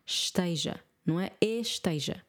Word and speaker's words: esteja: Normalmente os portugueses têm tendência a não pronunciar o E e apenas só o S. Esteja, não é? Esteja. Pode ser, mas normalmente nós não pronunciamos o E.